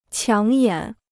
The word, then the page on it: 抢眼: qiǎng yǎn